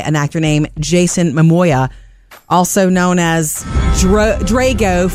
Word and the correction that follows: Drogo: from Game of Thrones. Drogo. I said it wrong. Drogo.